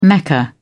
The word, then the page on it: mecca: British English